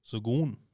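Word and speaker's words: Şengün: And then E-N-G-U with the two dots, and then N. – Sengun.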